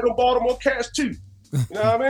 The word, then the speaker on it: Baltimore: You say Baltimore. You can hear the T, but you say it like the natives. They say Baltimore. You don't even hear a T when they say it.